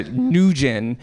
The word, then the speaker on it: Nguyen: That's Nguyen, because Vietnamese is a monosyllabic language. There is one syllable per word.